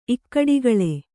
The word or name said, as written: ಇಕ್ಕಡಿಗಳೆ